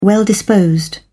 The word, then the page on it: well-disposed: British English